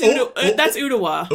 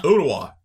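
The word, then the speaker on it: Ooltewah: I'm going to spell it. O-O-L-T-E-W-A. – Oh, that's Ottawa. – Ottawa, yeah.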